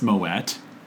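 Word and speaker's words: Moët: A-S-T-I, and to a lesser extent, Moet. – Moet. – Which is pronounced Moet. – So, at the end of episode 3, I gently corrected Michael when he said Moet, but...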